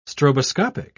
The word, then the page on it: stroboscopic: British English